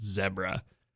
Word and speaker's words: zebra: Yeah, and in, and how zebra in posher places they pronounce it zebra.